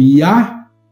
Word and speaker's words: Yah: Desnecessário mencionar o Yud e Re, pronunciado como Yah, porque ele constitui um nome divino separado, e além de ele ser parte do tetagrama.